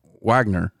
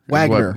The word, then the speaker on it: Wagoner: And then Wagoner, which is where a lot of my family's from. It's Wagner. – Wagner. Like the O is gone. – Right. – Like Wagner.